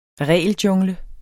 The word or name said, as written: regeljungle